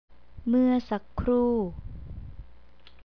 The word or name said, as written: muasakkru